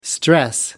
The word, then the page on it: stress: American English